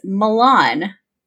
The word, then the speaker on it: Milan: So, your geographical sounding name is Milan. – Milan? – Mm-hmm. – As in M-I-L-A-N? – M-I-L-A-N. I think in Ohio or Illinois, they pronounce it Milan, but it's Milan.